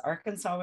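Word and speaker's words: Arkansaw: But okay, so it has Arkansas, but it's spelled with at the end S-A-W instead of S-A-S, and it's in Pepin County, and it says Arkansas, Wisconsin was named after the Arkansas River.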